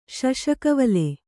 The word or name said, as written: ಶಶಕವಲೆ